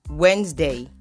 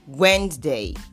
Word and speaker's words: Wednesday: This word is rightly pronounced as Wednesday. Wednesday. You only pronounce the second D. The first D is silent in the word. And for the S, you sound it as Z.